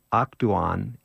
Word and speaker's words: Akdoğan: Nuriye Akdoğan, A-K-D-O-G-A-N-G, is silent, had to leave Nusaybin, and she lives with her family now in Kiziltepe.